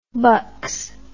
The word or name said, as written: bucs